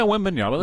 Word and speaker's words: Wembanyama: Am I saying it correctly? – Yeah, Wembanyama. That's fine.